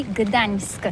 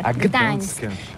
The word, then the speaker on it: Gdańsk: Ça s'écrit G-D-A-N-S-K. On dit Gdansk ou Gdansk, on prononce le G ou pas? – Oui, c'est pas très facile pour des étrangers, mais remarque bien que la langue polonaise, c'est la langue où on prononce chaque lettre qu'on écrit. C'est pourquoi on dit Gdańsk.